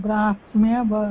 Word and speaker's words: ब्राह्मी: कारणं किम् इति अहमपि सम्यक् न जानामि लेखनावसरे कथं लिखामः हकारात् परं मकारं लिखामः किन्तु उच्चारणावसरे ब्राह्मी इति वदामः न तु ब्राह्मी ब्राह्मी इत्येव वदामः इत्युक्ते मकारात् परं हकारस्य उच्चारणं भवति